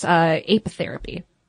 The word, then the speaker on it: apitherapy: There's even a name for this, apitherapy. Did I say that right? Yeah? Apiary? Apitherapy? Yeah, totally.